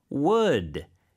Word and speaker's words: would: 자, that would. It sounds the same as 나무, wood. – 오, 맞아요. – But the spelling is different. W-O-U-L-D. – 맞습니다. 그래서 L이 묵음이에요. 발음은 안 네. 되지만 있어요, 스펠링은. That would be really helpful.